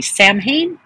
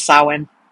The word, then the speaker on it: Samhain: Now it's spelled S-A-M-H-I-N, so you'd think it would be Samhain, but it's not. It's Samhain.